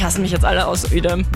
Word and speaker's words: Uedem: Er sagt, Uedem, was ich von Uedem ausgesprochen habe, wird Uedem ausgesprochen.